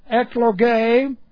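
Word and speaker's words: eklogē: Then we have ekloge. That's E-K-L-E-G long E. Ekloge.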